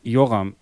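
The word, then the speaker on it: Yoram: Produktion war ja auch wieder, also neben Joram Globus. Wird er so ausgesprochen? – Joram.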